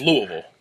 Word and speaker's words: Louisville: But, so, Louisville, it's Louisville. Right, it's how they say it.